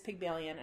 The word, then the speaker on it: Pygmalion: So my movie is Pygmalion, and I actually checked to see if I was pronouncing it correctly, because just because. And I am.